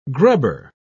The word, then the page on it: grubber: British English